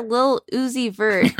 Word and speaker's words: Lil Uzi Vert: Rapper Lil Uzi Vert, did I say that right? – It just sounds funny.